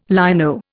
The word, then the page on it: lino: Indian